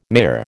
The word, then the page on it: mayor: American English